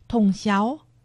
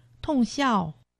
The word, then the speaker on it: 通霄: 在今天节目中介绍客家文化重点发展区苗栗县通霄镇，客家话四线腔发音统霄，海陆腔发音统孝，欢迎听众朋友们一同收听。